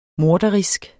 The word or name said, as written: morderisk